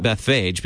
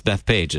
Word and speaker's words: Bethphage: I pronounce it Bethphage because there's a PH together, but I've heard it pronounced Bethpage as well.